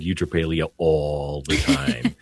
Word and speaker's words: eutrapelia: It's like a virtue called like eutrapelia.